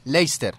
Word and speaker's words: Leicester: Es que se escribió así, Leicester, y se pronuncia Leicester. Como Gloucester, se pronuncia Gloucester.